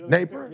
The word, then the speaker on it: Napier: Like, I told you, coach from Florida, Napier? Am I saying it right? – Napier. – Napier? – Yeah, Napier.